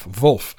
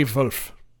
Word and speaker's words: Wulff: W-U-L-F-F. Wolf. I think that's how you say it in with the German accent. – Yeah, that sounds German to me.